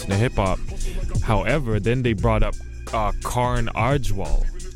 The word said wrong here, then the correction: Karan Aujla: I will that I'm saying his name right. But he's a Punjabi artist. Karan Aujla. Yes.